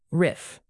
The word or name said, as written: riff